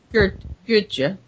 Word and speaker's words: Goedsche: And there's another guy, Goethe, or I don't know, how do you pronounce it? G-O-E-D-S-C-H-E. – Goethe. – Hermann Goethe, a Prussian.